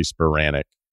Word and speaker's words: Spiranac: because I always thought it was Spiranac. And then Sruti's like, no, it's Spiranac. And we went back, because you'd like to know how to pronounce the guest's fucking name. And Paige was like, no, it's actually Spiranac.